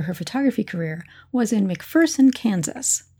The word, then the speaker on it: McPherson: I want to do a quick shout out of thank you to the librarians in McPherson, Kansas, for helping me understand that, as they put it, there's no fear in McPherson. So that's a great way to remember how to pronounce that town.